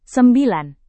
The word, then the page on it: sembilan: Indonesian